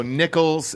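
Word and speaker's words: Nicholls: So Nichols, like the change, right? – Yeah. Okay. Well, it's spelled differently, but that's the way you say it.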